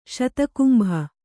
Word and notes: ಶತಕುಂಭ: kannada

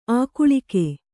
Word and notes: ಆಗಳಿಕೆ: kannada

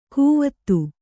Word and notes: ಕುವತ್ತು: Kannada

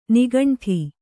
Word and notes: ನಿಗಂಠ: nigaṇṭha

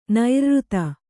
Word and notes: ನೈರ್ಋತ: nairřta

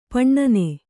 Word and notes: ಪಣ್ಣನೆ: paṇṇane

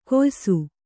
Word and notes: ಕೊಯ್ಸು: koysu

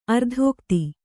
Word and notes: ಅರ್ಥಗತಿ: Kannada

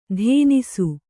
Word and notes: ಧೇನಿಸು: dhēnisu

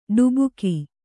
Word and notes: ಢಬಕು: ḍhabaku

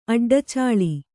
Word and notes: ಅಡ್ಡಚಾಳಿ: Kannada

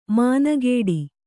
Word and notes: ಮಾನಗೇಡಿ: mānagēḍi

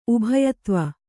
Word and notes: ಉಭಯತ್ವ: kannada

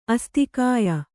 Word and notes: ಆಸ್ತಿಕ್ಯ: āstikya

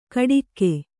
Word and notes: ಕಡಿಕ್ಕೆ: kaḍikke; Kannada

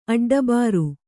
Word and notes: ಅಡ್ಡಬರ್: kannada

